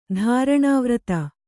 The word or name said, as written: ಧಾರಣಾವ್ರತ